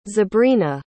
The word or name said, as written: zabrina